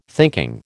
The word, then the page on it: thinking: American English